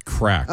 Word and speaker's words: craic: They call it Shades of Ireland, where you're going to see castles, cliffs, pubs, music, and crack. Is that C-R-A-I-C? Is that how I'm pronouncing it? – I don't think it's fun. Pronounced crack.